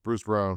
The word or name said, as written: Bruce Brown